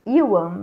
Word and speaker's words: Ewan: Tem a Toni Colette tem o Evan, como é que fala o nome dele? – Ewan McGregor.